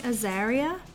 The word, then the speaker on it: Azariah: Would you say Azariah? I think. A, let's spell it. A Z A R I A H. Yeah. Yeah.